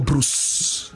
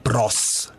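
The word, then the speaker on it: Broos: It's not Hugo Bruce. – What's it? – It's not Hugo Boss. – Yeah? – It's Hugo Bros.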